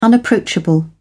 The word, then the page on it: unapproachable: British English